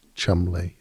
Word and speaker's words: Cholmondeley: or Cholmondeley is pronounced Chumley or Magdalene is pronounced Maudlin.